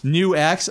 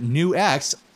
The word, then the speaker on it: NUX: So what's new with me is that I messed around with that Nux Solid Studio. – New X. – Apparently, that's another thing new. That's how you pronounce it. New X.